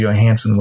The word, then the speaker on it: Johansson: Scarlett Johansson was the her. – I love that you go full. – What's the Swedish voice pronunciation? – Oh, Johansson.